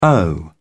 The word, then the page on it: oh: British English